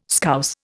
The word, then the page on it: Scouse: Indian